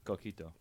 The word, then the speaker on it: coquito: Coquito.